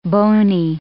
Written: bony

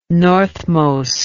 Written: northmost

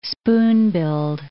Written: spoon-billed

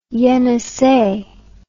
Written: enisei

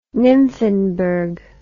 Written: nymphenburg

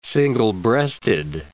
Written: single-breasted

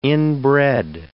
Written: inbred

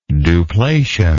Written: duplation

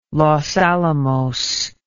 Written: los alamos

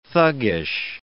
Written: thuggish

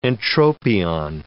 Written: entropion

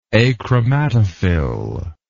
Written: achromatophil

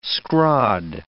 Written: scrod

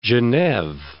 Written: Geneve

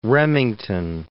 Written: Remington

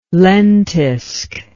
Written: lentisk

